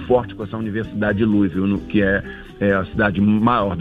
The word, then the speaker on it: Louisville: E eu tava vendo aqui também, de uma palestra que você fez na Universidade de Louisville. – Ah, isso, Louisville. – Essa pronúncia aí, você me corrige. – É a pronúncia que eles têm lá, porque o Louisville fica no Kentucky.